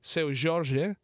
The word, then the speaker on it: Seu Jorge: As mentioned before, that was Mina do Condominio by Seu Jorge. Did I say that one correct? – Yes. No, you said it right.